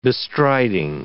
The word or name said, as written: bestriding